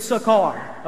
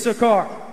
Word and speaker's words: Sychar: Say Sychar with me. Remember that. Some people may pronounce it different. You don't know the difference. It's Sychar, okay? It's Sychar.